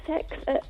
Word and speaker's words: CeX: We've got a sex. – A sex. – What? – What? You've got what? – Yeah, how do you pronounce that? – Sex. – No, C-E-X in Grantham Town Centre.